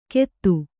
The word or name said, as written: ಕೆತ್ತು